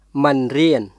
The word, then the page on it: មិនរៀន: ムン・リアン; mɯn riˑən